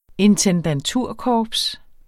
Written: intendanturkorps

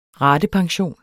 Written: RATEPENSIONEN

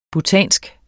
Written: BHUTANSK

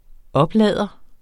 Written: oplader

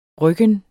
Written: rykken

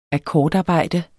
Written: akkordarbejde

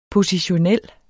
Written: positionel

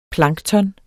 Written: plankton

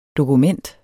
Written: dokument

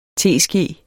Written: teske

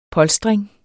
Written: polstring

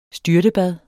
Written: STYRTEBADET

